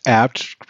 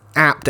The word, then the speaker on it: Abt: Isn't it pronounced apt because that's the family's name? – I was thinking that earlier too. I didn't want to say anything. – It's apt ABT.